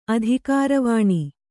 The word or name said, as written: ಅಧಿಕಾರವಾಣಿ